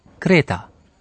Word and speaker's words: Kreta: إنها تنطق بالألمانية كريتا إذا الرجل يقول إنه من هذه الجزيرة التي أقام فيها ديدالوس أيضا Woher kommen Sie denn? Aus Kreta.